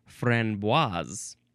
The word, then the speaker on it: framboise: the pronunciation says the first syllable is fron with an N, but the N is superscript, so it's just a very subtle N, fron. And then the second syllable is bois. You do actually pronounce the S, and I didn't know that, so next time I am talking about this framboise.